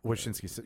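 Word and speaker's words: Wachowski: So is it Wachinski? The Wachinski brothers? – Sisters now. – Wachinski.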